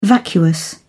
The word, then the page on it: vacuous: British English